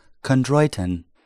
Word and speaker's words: chondroitin: and this is chondroitin. – Yeah, that sounds like a planet. – Chondroitin. So if you aren't into glucosamine, ask your vet about chondroitin.